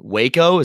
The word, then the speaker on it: Waco: Okay, so jumping right into it, I want to know what the atmosphere was like in Waco. Is that the right pronunciation? Waco, Texas? – That is, yeah, some call it Waco.